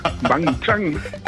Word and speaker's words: Wang Chung: Wang Chang. – Hast du noch ein bisschen mehr dazu auf Lager, außer dem Titel? Wang Chang hast du schon sehr schön ausgesprochen.